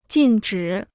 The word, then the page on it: 禁止: jìn zhǐ